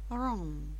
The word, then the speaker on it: wrong: Буквы сочетания WR произносится только звук R. Буква W не произносится. Wrong, right.